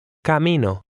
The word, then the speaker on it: Camino: Watch the word stress, which is usually on the next to last syllable. – Camino.